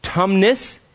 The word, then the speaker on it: Tumnus: Mr. Tumbus. And what? No, I didn't say it right. What is it? Tumnus.